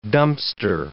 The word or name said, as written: Dumpster